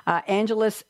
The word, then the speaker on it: Angelus: Angelus, am I pronouncing his name right, Kelly? – Yep.